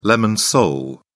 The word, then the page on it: lemon sole: British English